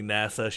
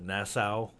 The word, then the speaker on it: NASA: Okay, yeah, I mean, I thought, I thought it was interesting how Ivanka, instead of saying NASA, she pronounced it Nassau, like it was in the Bahamas.